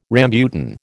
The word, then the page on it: rambutan: American English